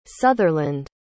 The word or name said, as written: sutherland